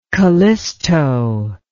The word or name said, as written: kallisto